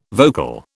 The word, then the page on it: vocal: British English